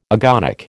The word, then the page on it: agonic: American English